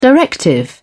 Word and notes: directive: British English

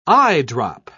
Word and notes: eyedrop: British English

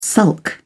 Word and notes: sulk: British English

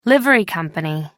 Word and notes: livery company: American English

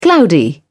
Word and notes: cloudy: British English

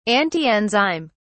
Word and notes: antienzyme: British English

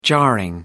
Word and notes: jarring: American English

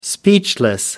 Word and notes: speechless: American English

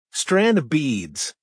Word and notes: strand of beads: American English

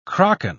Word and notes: kraken: British English

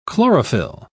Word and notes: chlorophyl: British English

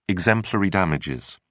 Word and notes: exemplary damages: British English

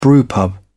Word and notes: brewpub: British English

